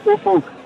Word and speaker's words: Ufuk: It's Ufuk, but we all call him Ufi to sort of save the embarrassment. Ufie. – Has anyone ever said the magic word? Because it's spelt U F U K.